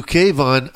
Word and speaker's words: Kayvon: No, my name is Kayvon, and I spelled it with a dash so people pronounce it. It's really an E right there, but people call me Kayvon and Kayvon.